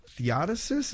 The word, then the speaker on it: Theodosius: In retaliation, Emperor Theodosius, is that how I say his name? – That sounds right, yeah.